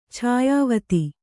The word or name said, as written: ಛಾಯಾವತಿ